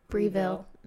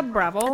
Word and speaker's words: Breville: It's a Breville. I always say it wrong. – It's a Breville.